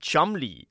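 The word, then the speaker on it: Cholmondeley: That's Marchioness of Chumley, which is spelled Cholomandilly. Cholomandilly. It's pretty much the same way it's pronounced Worcester, but spelled Worcestershire. Worcestershire.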